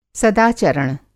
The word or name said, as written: सदाचरण